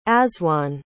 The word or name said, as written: azwonne